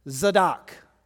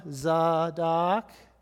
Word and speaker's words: Zadok: So this is the word Zadok. Zadok. Zadok means righteousness. And you can hear the same, we would call it maybe a short A sound, Zadok.